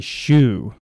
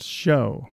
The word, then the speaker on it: shew: I have a little pet peeve when people like read verses out of the New Testament and the word S-H-E-W is there and they say shoe. It's pronounced show.